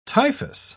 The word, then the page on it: typhous: British English